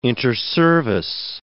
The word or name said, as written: interservice